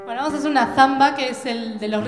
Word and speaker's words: zamba: No es la zamba brasilera, esta se escribe con Z. Pasa que como nosotros no pronunciamos bien la Z, no se entiende bien, pero es zamba.